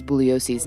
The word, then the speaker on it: Bugliosi: And the two attorneys constantly jab at each other, like when Spence mispronounces Bugliosi's name. – The name is pronounced Bugliosi. The G is silent.